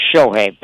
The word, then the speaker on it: Shohei: It's actually Shohei. Pardon me, I mispronounced.